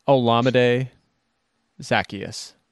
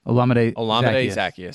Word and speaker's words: Olamide Zaccheaus: Olamide Zaccheaus. – Olamide Zaccheaus.